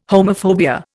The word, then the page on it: homophobia: Indian